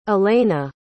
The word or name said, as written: allayna